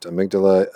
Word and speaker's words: amygdala: The amygdala, pronounced amygdala, albeit a small almond-shaped portion of the brain, is important in emotional function.